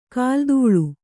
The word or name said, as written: ಕಾಲ್ದೂಳಿ